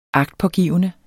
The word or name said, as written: agtpågivende